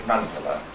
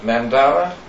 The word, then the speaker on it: mandala: Now, concerning the pronunciation, in Sanskrit, the A's are pronounced as little U's. So in Sanskrit you would say mandala. But mandala, according to the Oxford English Dictionary, is a perfectly acceptable pronunciation.